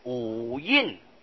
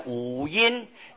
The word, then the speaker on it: 五阴: 五印，五印。那么如果照字念呢，往往会念成五阴，念成那个阴阳的阴啊，这里不念作阴，应当是印字，就是遮印的印相同的印啊。